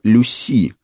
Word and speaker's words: Люси: Настоящее имя лошади было Люси, с ударением на последний слог.